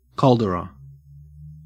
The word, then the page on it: caldera: Australian English